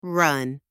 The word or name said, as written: run